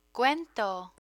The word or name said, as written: cuento